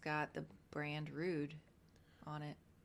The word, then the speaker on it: Rhude: I think it's the brand is pronounced Rude, R-H-U-D-E.